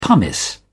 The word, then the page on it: pumice: British English